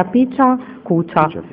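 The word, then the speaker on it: Pecha Kucha: Francesco Iannella, questa serata che ha un nome un po' particolare, si pronuncia Picia Cucia.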